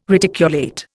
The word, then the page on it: reticulate: Indian